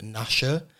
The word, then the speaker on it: Nasha: I'm here with Nasha. I realised I should have asked before we started, is that the correct pronunciation? – yeah, yeah, it's the same as the dog from Dennis.